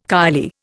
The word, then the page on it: kylie: Indian